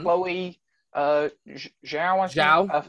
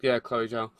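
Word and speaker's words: Chloé Zhao: Chloe Zhao, who directed Nomadland. – Yeah, Chloe Zhao.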